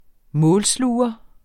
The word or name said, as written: målsluger